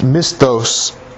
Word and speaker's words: misthos: The word reward with regards to a heavenly reward is pronounced misthos, and it's a payment for your service in heaven.